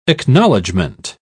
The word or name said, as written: acknowledgement